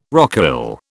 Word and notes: rocaille: British English

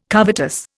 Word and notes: covetous: Indian

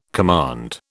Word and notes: command: British English

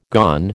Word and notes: gone: American English

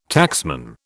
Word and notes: taxman: British English

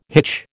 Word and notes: hitch: American English

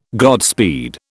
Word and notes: Godspeed: British English

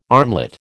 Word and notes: armlet: American English

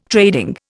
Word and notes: trading: Indian

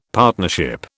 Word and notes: partnership: British English

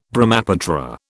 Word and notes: Brahmaputra: British English

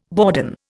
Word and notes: bourdon: Indian

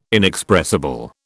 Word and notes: inexpressible: British English

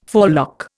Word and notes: forelock: Indian

